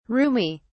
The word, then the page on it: roomie: British English